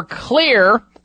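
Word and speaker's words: Xlear: When you go in and you've got some nasal problems, ask for Clear, spelled with an X for xylitol, Clear Nasal Sprays.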